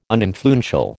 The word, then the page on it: uninfluential: American English